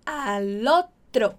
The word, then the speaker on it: al otro: Cuidado com a pronúncia. Al otro... Al otro, não ao outro. Al otro, junto L com o.